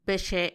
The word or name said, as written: Bexe